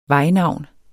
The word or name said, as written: vejnavn